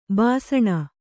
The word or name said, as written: ಬೇಸಿನ್